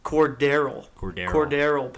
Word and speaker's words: Cordarrelle: Cordell Patterson. Did you guys see we're saying his name wrong? – What is it? – Cordarrelle Patterson. – That's what we have been saying. – Yeah, that's we've been saying. – Well, like Cordell. Name. I always say Cordell. – Yeah, you've been saying. – Yeah, you've been saying it wrong.